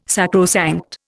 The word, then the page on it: sacrosanct: Indian